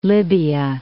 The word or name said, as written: Libya